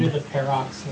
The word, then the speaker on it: Pharrox: P-A-R-R-O-X. – That works fine. P-A-R-R-O-X. – Yeah, Parrox. – With a silent H. – Yeah, with a silent H in there somewhere. P-H-A-R-R-O-X. Parrox.